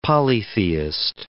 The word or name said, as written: polytheist